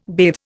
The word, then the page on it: bathe: Indian